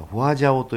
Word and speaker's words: 花山椒: でちょっともっとスパイシーな感じがして、これも豆板醤とかね、まあ、いろんな、あの辛子味噌があるけどもそれと一緒に合わせてですね、これフォアジャオと読むんですけど、花山椒と書いてこれフォアジャオと言うんですけど、四川料理の中でね、麻婆豆腐ってなりますね。最後にこのフォアジャオを振るんですよ。だからフォアジャオはね唇のところに当てるとすっきりするんですね、ピリピリっとしてね唇がね。